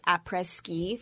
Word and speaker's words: après-ski: Actually, fun fact: Becca wanted all of us to dress like après ski for her night before the wedding party.